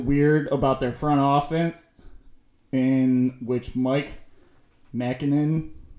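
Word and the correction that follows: Maccagnan: Am I saying that right? – McCagnon.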